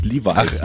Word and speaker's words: Levi's: Levels, Levi's. – Ach, Levi's, ja, Levi's. – So wird man es ja aussprechen.